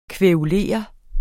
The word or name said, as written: kværulere